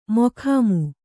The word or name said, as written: ಮೊಖಾಮು